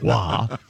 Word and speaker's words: Roy: But and it's pronounced Roy, by the way. – It's wah. I know enough to say wah.